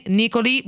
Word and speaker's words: Nicolì: Chiedevo alla mia assistente: ma come si pronuncia, che non me lo ricordo, Nicolì o Nicoli? Nicolì, e quindi abbiamo accontentato insomma la pronuncia.